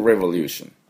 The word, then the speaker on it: Revolution: この Peaceful Revolution、これちょっと発音いきたいと思います。 Revolution というのは R-E-V-O-L-U-T-I-O-N ですね。 R と L があるのはですね、これ非常に難しそうなんですが、その後 V もあります。だから Rev ね、 R-E ですね、で V の音 V これを、要注意、していただきたいと思います。